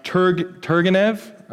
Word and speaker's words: Turgenev: He's a Russian poet, Turgenev.